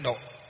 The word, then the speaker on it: độ: Cái chữ độ vẫn là chữ thổ ấy, nhưng mà trong Phật giáo đọc là chữ độ.